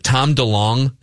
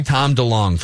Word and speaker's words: DeLonge: Is it Tom DeLonge? Is that how you pronounce it? – Sounds good to me. – Tom DeLonge from Blink-182.